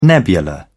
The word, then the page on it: nebula: British English